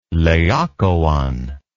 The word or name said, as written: laokoon